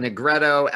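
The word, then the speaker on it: Nigredo: You talk about the three steps being, and I'm going to mispronounce this, you talk about Negretto, Albedo, and Ribetto. – You did pretty good.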